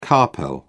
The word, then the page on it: carpel: British English